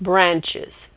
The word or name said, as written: branches